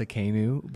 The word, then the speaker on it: Tsidkenu: And I know it's pronounced Tsidkenu, but it's spelled with a T.